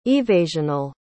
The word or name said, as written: evasional